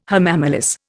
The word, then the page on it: hamamelis: Indian